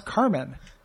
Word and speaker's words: Carman: In the last episode, I talked about how I thought he'd be named Carmen, but his stage name is Carman. That's not his name. His name is Carmen, but he changed it to Carman. – Uh-huh. – But it's pronounced Carmen.